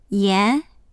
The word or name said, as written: yan2